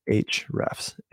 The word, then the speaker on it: Ahrefs: I'll spell it for you here if I pull it up. A-H-R-E-F-S. Ahrefs. A-H-R-E-F-S.